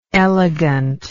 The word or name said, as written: elegantly